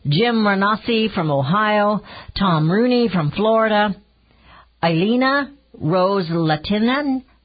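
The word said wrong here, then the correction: Ileana Ros-Lehtinen: from Florida. I'm sure I didn't pronounce that right. It's Elena Ross Latinen from Florida.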